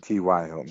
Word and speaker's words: T.Y. Hilton: T.J. Hilton just scored a touchdown, Andrew. – T.Y. Hilton.